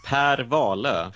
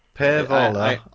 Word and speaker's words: Wahlöö: Well, it is Maj Sjöwall och Per Wahlöö. – Per Wahlöö. – Yeah, it's the Ö sound. It really gets English-speaking people I've heard before. Maj Sjöwall, Per Wahlöö.